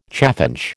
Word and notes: chaffinch: American English

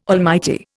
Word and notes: almighty: Indian

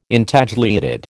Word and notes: Intagliated: American English

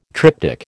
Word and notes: triptych: American English

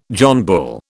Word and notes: John Bull: British English

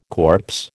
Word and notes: corpse: American English

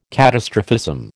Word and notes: catastrophism: American English

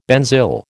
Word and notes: benzyl: American English

